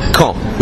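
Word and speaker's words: Caen: It's quand, like as if you're asking when in French. It's the same pronunciation.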